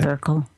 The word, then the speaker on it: Merkel: Am I pronouncing it right? – Merkel. – Merkel.